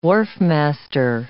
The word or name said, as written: wharfmaster